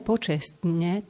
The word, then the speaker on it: počestne: Žijeme počestne, tak isto musíme využiť, že je tam trojica spoluhlások, ktoré nezaznejú všetky, ale zadržaná doba na vyslovovaní t počestne, lebo potom by mohlo vzniknúť z toho počesne. Počesne je absolútne nezmyselné slovo.